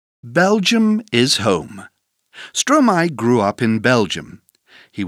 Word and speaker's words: Stromae: Turn the page in your magazine to learn more about Stromae. – 1. My plus stro. Stromae is the word maestro with the last syllable first.